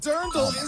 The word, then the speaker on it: Dirndl: I said it wrong the other day. I called it a dreidel. – not a dreidel. – It's drindel. No, Dirndl is the correct answer.